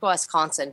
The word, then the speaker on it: Wisconsin: How do you Wisconsin? – Say it? – Wisconsin. W-I. You say it like a Wes.